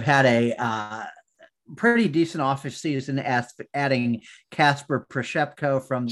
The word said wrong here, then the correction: Przybylko: Przybylko. Kasper Przybylko. Oh, come on. How dare you mispronounce that?